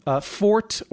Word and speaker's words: forte: Fort or versus forte. Fort as an English pronunciation of forte, meaning strong in Latin and the Latin languages. Or frezzo in Italian. Fort, as an English pronunciation, is long-standing in our language.